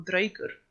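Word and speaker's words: draugur: Och ja, det uttalas dröjgar och dröjgur. Okej.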